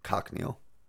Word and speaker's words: cochineal: So it's a dye called carmine or cochineal.